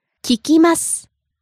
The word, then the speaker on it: 聞きます: 聞きます The present polite form of the verb 聞く to listen.